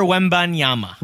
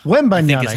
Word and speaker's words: Wembanyama: You can correct Victor Wembanyama. – Wembanyama, excuse me. – Wembanyama. – Yes, you're right. You're right. – Don't say that five times fast. Wembanyama.